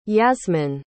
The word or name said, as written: Yasmin